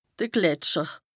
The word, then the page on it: de Gletscher: Bas-Rhin; Strasbourg